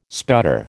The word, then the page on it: sputter: American English